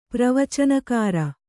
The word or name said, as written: ಪ್ರವಚನಕಾರ